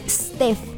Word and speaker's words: Steph: En Facebook me encuentras como @StephAmorFM, S-T-E-P-H, así se escribe mi nombre, Steph, sin E, Steph, ¿ok? PH en lugar de F, ¿vale, vale?